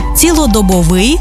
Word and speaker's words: цілодобовий: Отже, цілодобовий та щодобовий, 100-відсотковий, ринковий, одноразовий та багаторазовий, наскрізний та вишиваний.